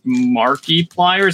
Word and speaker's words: Markiplier: I believe his name is Marky Pliers. Is that how you pronounce it? – Mark yeah, that sounds about right.